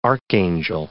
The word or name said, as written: archangel